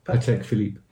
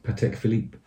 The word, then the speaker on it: Patek Philippe: Philip. – Patek Philippe. – Yeah, how do you say it again? – Patek Philippe. – Patek Philippe. Mm.